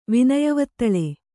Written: ವಿನಯವತ್ತಳೆ

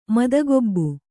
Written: ಮದಗೊಬ್ಬು

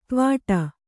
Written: ತ್ವಾಟ

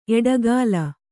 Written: ಎಡೆಗೋಲ್

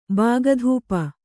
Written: ಬಾಗಧೂಪ

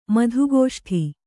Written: ಮಧುಗೋಷ್ಠಿ

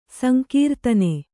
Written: ಸಂಕೀರ್ತನ

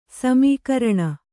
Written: ಸಮಾಕೀರ್ಣ

